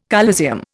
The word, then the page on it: coliseum: Indian